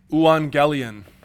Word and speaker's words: euangelion: In Greek, this is the word, euangelion, spelled like that. It's not really spelled like that in Greek, but that's how you pronounce it.